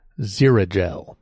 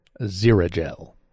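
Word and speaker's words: Xerogel: Zirigel. Zirigel.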